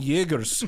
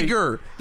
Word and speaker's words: Jaeger: Jaeger. With a J. There's a soft J. – J-Y. – A strong Y at the beginning. – It's a J. It's a soft J. – Yeah, it's literally J. Gur.